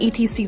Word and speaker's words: etc.: 那还有我们常见的，比如说，呃，在写作啊，或者是在给别人发简讯的时候，想要写“ 等等” 啊这个词来表达的话，我们通常会看到 e t c，对不对？大家这个时候不要，嗯，非常秀逗的一个字一个字的念啊，它正确的发音是 etc，etc，它其实也是拉丁文啊，etc 的缩写，相当于是 and so on or。